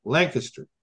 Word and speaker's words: Lancaster: So you put a kiss in the middle with the accent on the first syllable, Lancaster.